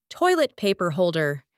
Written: toilet paper holder

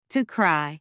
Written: to cry

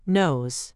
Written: nose

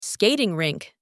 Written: skating rink

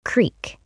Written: creek

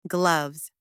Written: gloves

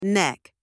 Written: neck

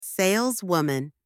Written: saleswoman

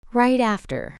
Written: right after

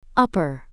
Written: upper

